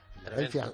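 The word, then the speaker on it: Philadelphia: Sí, Filadelfia, las de las dos F, dicen, y no tiene ninguna. Pero se pronuncia Filadelfia, PH.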